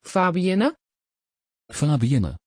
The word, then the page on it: fabiënne: Dutch